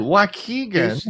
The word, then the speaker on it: Waukegan: Waukegan.